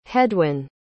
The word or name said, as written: heddwyn